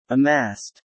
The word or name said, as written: amassed